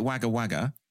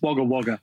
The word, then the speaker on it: Wagga Wagga: I called it Wagga Wagga, and apparently it's not that. And no. – What is it? – And that's 100% how it's spelled, but it's pronounced Wagga Wagga.